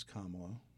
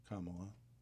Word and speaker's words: Kamala: We're getting reminders on pronunciation of Kamala Harris. It is Kamala. Mamala. Kamala. Just reminding everyone.